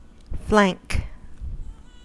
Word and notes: flank: American English

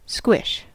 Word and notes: squish: American English

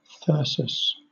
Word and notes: thyrsus: British English

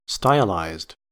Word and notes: stylized: American English

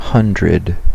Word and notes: hundred: American English

